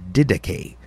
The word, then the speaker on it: Didache: In fact, the first reference dates from before the year 100 in the Didache. Didache, D-I-D-A-C-H-E is the way you spell it in English if you want to look it up. Didache, D-I-D-A-C-H-E.